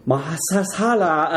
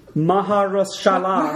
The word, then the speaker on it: Mahershala: There is also Mahershala Ali. – Okay, wait a minute. – Of course, that guy. – Wait, how do you say his name? – Mahershala.